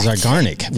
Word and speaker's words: Zeigarnik: Zeigarnik.